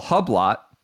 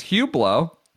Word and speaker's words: Hublot: He's got Hublot, which I have to admit, my pronunciation adventures, I would absolutely call Hublot if I had not listened to, you know, heard it referenced in several rap songs and things like that. It's Hublot.